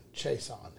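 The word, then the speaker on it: Chaisson: That is Kalavon Chaseon is how you say it.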